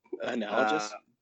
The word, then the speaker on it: analogous: That's very analogous. Is that how you say it? – Analogous? – That we will accept.